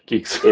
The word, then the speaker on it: Guix: It's Geeks SD for System Disk. – How the hell do you get Geeks out of that? G-U-I-X. Oh, yeah. I see how you did that. Geeks. – It is French. I had to look it up and listen a couple times, but it's a French distribution. It was started originally. I don't have the details off the top of my head. But it is pronounced Geeks.